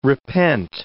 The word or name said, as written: repent